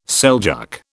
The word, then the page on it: Seljuk: British English